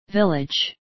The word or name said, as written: village